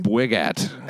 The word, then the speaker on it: Bwigat: Bwigat. – How do you spell that? – B-W-I-G-A-T.